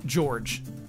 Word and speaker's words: Jorge: The Spartan in Reach is named or pronounced George. Spelled with a J. Not pronounced Jorge, but that's George. George cool.